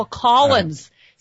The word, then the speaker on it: Collins: It's like people ask me, how do you spell Collins? C-O-L-L-I-N-S. And then I go, C-Z-Y-K. The C-Z-Y-K is silent. – C-Z-Y, what is it? C-Z? – You got to keep up, Peter. – C-Z-Y-K.